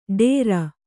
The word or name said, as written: ಢೋರ